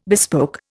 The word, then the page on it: bespoke: Indian